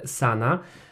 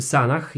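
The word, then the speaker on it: Sanah: jak i płytę Sana, czy Sanach, jak to woli wymawiać. Ja słyszałem niejednokrotnie w radiostacjach mówi się Sanach, chociaż e, w sumie równie dobrze można mówić Sana, bo H się nie wymawia no e, tak, z angielska.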